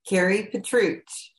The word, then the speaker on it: Kari Petruch: Kari Petruch.